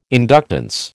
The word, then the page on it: inductance: American English